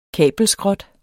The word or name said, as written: kabelskrot